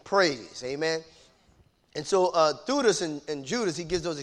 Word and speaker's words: Thaddeus: Thutis. We normally pronounce that Thaddeus, but it's Thutis.